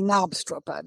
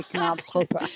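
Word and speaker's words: Knabstrupper: Well, the British and the Danes and the Americans say Knobstrooper. The K is silent.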